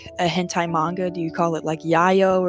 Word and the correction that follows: yaoi: And I think what you mean yayo, you're referring to yaoi, which is a specific genre of boy on boy stuff.